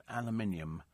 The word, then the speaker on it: aluminium: It's pronounced aluminium.